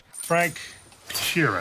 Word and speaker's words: Frank Sheeran: Frank Sheeran. Am I saying that right? – Yeah, you said it right.